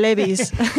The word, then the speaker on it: Levi's: Ah, que tú lo pronuncias bien, vamos a decirlo Levi's en español, Levi's.